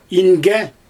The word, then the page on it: inge: Xyzyl